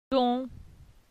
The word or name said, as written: Don